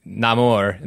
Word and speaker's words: Namor: And that's how he takes his name, Sin Amor, and says Namor in the way he pronounces it. Because I did wonder if there would be a Spanish pronunciation of Namor and using Namor in this movie.